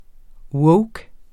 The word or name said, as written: woke